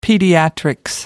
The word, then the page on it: pediatrics: British English